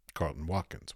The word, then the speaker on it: Carleton Watkins: Carlton Watkins.